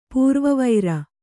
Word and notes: ಪೂರ್ವವೈರಿ: pūrva vairi

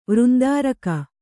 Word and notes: ವೃಂದಾರಕ: vřndāraka

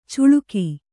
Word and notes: ಚುಳುಕಿ: cuḷuki

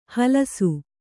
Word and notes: ಹಲಸು: Kannada

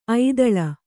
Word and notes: ಐದಾಳಿ: aidāḷi

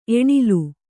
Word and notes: ಏಣೇಲು: ēṇēlu

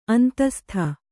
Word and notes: ಅಂತಃಸ್ಥ: kannada

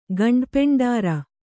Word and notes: ಗಂಡಪೆಂಡರ: gaṇḍa peṇḍaara

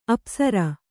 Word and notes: ಅಪಸರ: Kannada

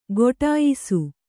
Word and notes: ಕೊಟಾಯಿಸು: koṭāyisu

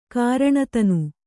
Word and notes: ಕಾರಣತನು: kāraṇa tanu

